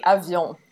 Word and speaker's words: Avion: So with that, we will now crossfade, just like the album does, into Nature Sounds and Par Avion, or Avion, however you want to pronounce things. I'll say Avion. – It's definitely Avion, if you want to know.